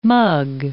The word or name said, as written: mug